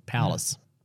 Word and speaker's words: Paulus: That's just what, how you pronounce it in Greek, Paulus.